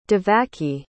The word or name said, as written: Devaki